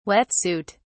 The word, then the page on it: wet suit: British English